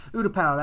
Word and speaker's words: Utapau: Utapau, that's how that's pronounced.